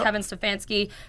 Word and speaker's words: Stefanski: Kevin Stefanski. Am I saying that right? – Yep. – Kevin Stefanski.